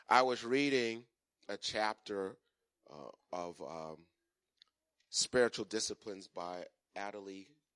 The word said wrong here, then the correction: Adele: Calhoun. Did I pronounce her name right? Adele Calhoun.